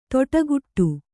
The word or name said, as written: ತೊಟಗುಟ್ಟು